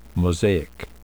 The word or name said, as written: mosaic